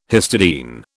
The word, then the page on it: histidine: British English